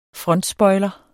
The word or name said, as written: frontspoiler